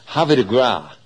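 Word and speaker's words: Havre de Grace: The Grade One Apple Blossom at Oak Lawn went to, now I'm going to pronounce it the way the old timers pronounced it back when they were racing there. Havre de Gras.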